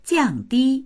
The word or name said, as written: jiang4di1